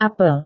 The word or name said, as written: apel